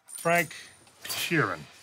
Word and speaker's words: Frank Sheeran: Frank Sheeran. I saying that right? Yeah, you said right.